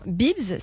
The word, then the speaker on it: Beebs: Euh, par exemple, l'application Bibs, ça s'écrit B-E-E-B-S, elle permet aux particuliers d'acheter et de vendre tout un tas de choses pour les enfants et notamment des fournitures scolaires.